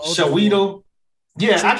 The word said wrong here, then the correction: Chiwetel: It's Chiwetel.